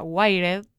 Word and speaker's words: Wired: Y lo que filtra esta chica, que después es chequeado por la revista que nunca me sale, cómo se pronuncia, Wired. – Sí, no Wired, sí.